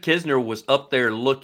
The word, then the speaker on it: Kisner: The Andrew, I can never say his name. Nitzer? Kinzer? – Kisner. – Kisner. – Yep.